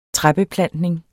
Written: træbeplantning

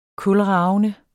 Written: kulravende

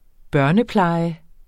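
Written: børnepleje

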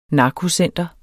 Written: narkocenter

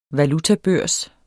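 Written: valutabørs

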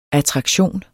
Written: ATTRAKTIONERNES

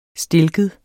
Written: stilket

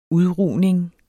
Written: UDRUGNING

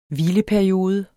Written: hvileperiode

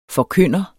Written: forkynder

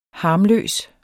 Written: harmløs

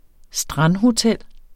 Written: strandhotel

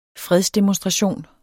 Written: fredsdemonstration

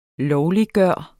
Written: LOVLIGGJORT

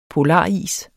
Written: polaris

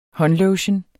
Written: håndlotion

